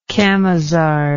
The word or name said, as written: camisard